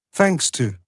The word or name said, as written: thanks to